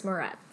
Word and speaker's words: Moretz: Chloe Grace Moretz.